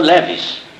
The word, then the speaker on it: Leves: E o meu nome se pronuncia assim. Eu sou filho de judeus russos, eu sou Leves. Eu sou Leves e sou Milestone mesmo.